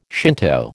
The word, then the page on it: Shinto: American English